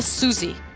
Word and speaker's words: Siouxsie: É, embora você escreva Siuxi, a pronúncia correta é Suzy.